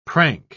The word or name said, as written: prank